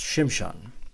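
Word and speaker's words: Shimshon (שמשון): Samson's name in Hebrew is pronounced Shimshon, and literally means of the sun.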